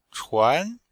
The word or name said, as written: chuan2